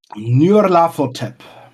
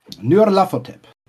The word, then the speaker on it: Nyarlathotep: Nyarlathotep. – Spricht man den so? – Nyarlathotep.